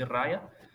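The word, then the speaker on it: Raja: escreve-se com J, mas pronuncia-se Raia, que é esposa também do grande escritor, o Domenico Starnone.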